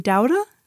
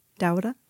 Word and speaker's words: Douda: Um, my name is Emily Douda. So that's my last name, Douda. Douda.